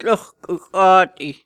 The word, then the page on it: Tluxhu Xati: Snake Island